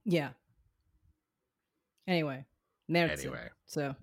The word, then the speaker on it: Märzen: So, learning Märzen.